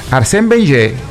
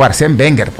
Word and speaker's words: Arsène Wenger: ¿Arsène Wenger o Arsène Wenger? Depende de cómo ustedes quieran pronunciar el apellido, si lo quieren pronunciar en francés o como lo pronunciaban en la Premier.